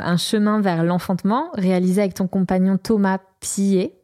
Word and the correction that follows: Piette: je ne sais pas si je le prononce bien. Piette. Piet. Alors Thomas Piette,